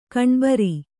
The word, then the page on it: ಗಣಬರು: gaṇabaru